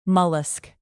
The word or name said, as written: mollusc